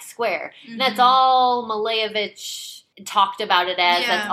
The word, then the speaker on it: Malevich: Malevich, yeah.